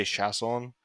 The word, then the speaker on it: Chiasson: Yeah, people pronounce it differently, like all over. I'd just say Chiasson, but you can say.